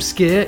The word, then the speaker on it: skyr: If you want to try some skier, it's spelled S-K-Y-R.